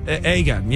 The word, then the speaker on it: Aegon: How do you say this? – Aegon, yeah.